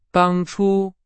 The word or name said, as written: 当初